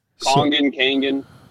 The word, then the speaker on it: Kangen: So this is, forgive me if I say it wrong, is Kangen water, correct? – Yes. Kangen.